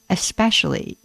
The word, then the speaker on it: especially: But there is no X in especially. It's especially, not especially.